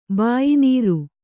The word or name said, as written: ಬಾಯಿನೀರು